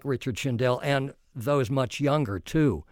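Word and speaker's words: Shindell: Can you tell me about the headliner, the biggest name on your poster, Richard Schindel? Is that how you say it? Yeah, he's been around 40 or 50 years.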